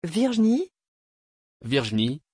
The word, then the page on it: virgenie: French